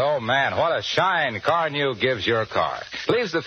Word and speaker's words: Carnu: More car owners buy Johnson's Car New, you know, than any other car polish. It's spelled C-A-R-N-U.